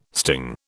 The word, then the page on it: sting: British English